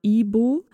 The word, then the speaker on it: Igbo: Ihre Familie gehört zur Bevölkerungsgruppe der Igbo. Es wird Igbo geschrieben, aber das G spricht man nicht mit.